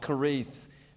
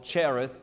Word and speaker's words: Cherith: And last Wednesday night, we got on board the bus with Elijah, and we went down to a place called Kareth. Many people call it Cherith, but it's actually pronounced Kareth.